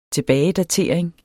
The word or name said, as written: tilbagedatering